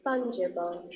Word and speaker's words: fungible: فانگیبل فانگیبل تلفظش رو میخوام فانجیبل فانجیبل